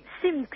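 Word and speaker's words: Tzimx: Se pronuncia Tzim, Simx, T-Z, Simx. Para que se visualice bien, es un Z-I-M, Zim, con una T adelante y una X atrás.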